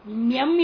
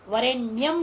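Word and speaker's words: वरेण्यम्: तथापि न्यम इत्यत्र वरे न्यम इत्यत्र भावनया नियम इति स्वर द्वयज्ञेय, भावना पूर्वक केवल उच्चारण पूर्वक नहीं, इसलिए भावनया बोल रहे नच उच्चारण है ना, उच्चारण कर दोगे तो अपोच्चारण हो जाएगा, पाप लगेगा उल्टा, ई का उच्चारण करना नहीं मंत्र का बीच में, लेकिन ऐसे भावना करना या ई छिपा हुआ है,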